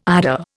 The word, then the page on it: eider: Indian